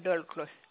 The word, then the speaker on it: Daulcle: Je suis madame Dolk. – Madame? – Madame Dolcle. – Ça s'écrit? – D-A-U-L-C-L-E.